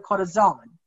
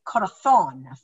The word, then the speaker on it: corazón: Their Z's are pronounced like a th. So in Mexico, they would say like a heart is called a corazón, because they say the Z. But in Spain, they would say corazón, thon.